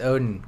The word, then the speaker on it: Odin: That is Odin, God of Gods.